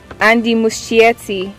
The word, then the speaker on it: Muschietti: Help us pronounce this name. – God, I've caught you now. Dracarys, the name. Kill it. – Andy Muschietti.